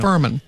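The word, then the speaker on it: Fuhrman: Yesterday, we learned that Mark Farman, how do you say his name? Farman? That guy from the O.J. Simpson trial. – Yeah, the cop, right. – Yeah, he died. – Mark Furman. Yeah. – Furman.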